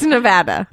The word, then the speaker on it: Nevada: They are losing Nevada. – Meaning. – Nevada. – It's it's Nevada.